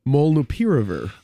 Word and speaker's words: Molnupiravir: Molnupiravir.